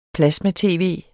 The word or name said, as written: plasma-tv